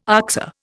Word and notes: oxer: Indian

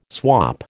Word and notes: swap: American English

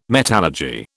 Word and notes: metallurgy: British English

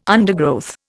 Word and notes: undergrowth: Indian

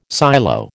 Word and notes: silo: American English

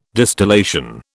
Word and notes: distillation: British English

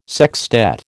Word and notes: sextet: American English

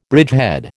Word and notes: bridgehead: American English

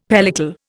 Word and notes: pellicle: Indian

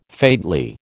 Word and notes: faintly: American English

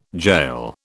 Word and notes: jail: British English

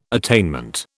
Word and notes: attainment: British English

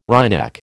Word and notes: wryneck: American English

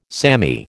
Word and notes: samey: American English

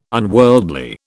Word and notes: unworldly: British English

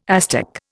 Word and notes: Aztec: Indian

